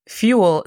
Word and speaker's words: fuel: Fuel significa combustible. La pronunciación fonética es F-I-U-E-L.